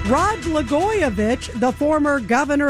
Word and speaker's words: Blagojevich: Well, Blagojevich rhymes with Katzen Matidis.